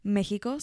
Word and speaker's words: Mexico: Mexico, sorry, not pronouncing it the white way anymore.